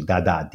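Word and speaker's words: Dadá: And she was transculturated with the Virgin of the Rosary, Dada, D-A-D-A, with an accent on the A.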